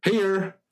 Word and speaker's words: Hehir: Heher. – Heher. – It's spelled H-E-H-I-R.